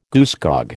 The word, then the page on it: goosegog: American English